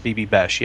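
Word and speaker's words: Bibi Besch: And is this one, talking about pronouncing things, is it B.B. Besh? – That's what I thought, B.B. Besh, yes.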